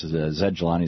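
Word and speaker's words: Zaid Jilani: Zed Jelani is spelled ZAIDJILANI.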